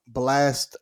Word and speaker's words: Blxst: My three, I got Blast album. It's spelled BLXST. Once again, that's BLXST.